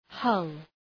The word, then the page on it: hull: hʌl